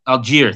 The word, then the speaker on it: Allgeier: but Tyler Allgeier. – Allgeier, thank you.